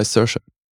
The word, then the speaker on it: Saoirse: Das ist halt wie bei Saoirse. Das ist das O ist einfach quasi stumm.